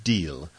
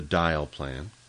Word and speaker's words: Dyle: This was the so-called Dyle plan, that's D-Y-L-E, and it's pronounced in French deal, but we'll call it the Dyle plan.